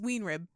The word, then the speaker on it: Weinrib: Is it Weinrib? – It's Weenrib. – Huh. – It's spelled like, it looks like it should be Weenrib or Weinrib? – Weinrib or something like that. It's Weenrib.